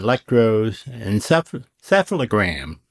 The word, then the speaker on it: electroencephalogram: get an electroencephalogram.